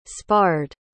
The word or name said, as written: sparred